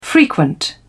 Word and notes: frequent: British English

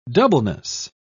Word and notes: doubleness: British English

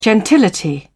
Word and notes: gentility: British English

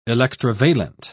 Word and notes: electrovalent: British English

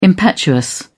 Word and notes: impetuous: British English

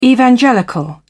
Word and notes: evangelical: British English

